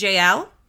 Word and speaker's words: Jael: And this woman, I think you pronounce it Jael, J-A-E-L.